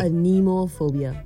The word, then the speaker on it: anemophobia: Anemophobia. – Anemophobia? Mm. Like Finding Nemo? Nemophobia? – Anemophobia. – First was nomophobia, now anemophobia. So Nemo, how do you spell it? – Anemophobia. A-N-E-M-O-P-H-O-B-I-A.